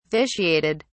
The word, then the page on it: vitiated: British English